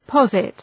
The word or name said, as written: posit